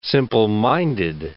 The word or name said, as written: simpleminded